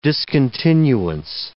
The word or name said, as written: discontinuance